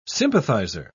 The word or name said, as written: sympathizer